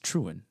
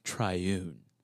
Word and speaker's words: triune: It's not pronounced truan, it's pronounced triune.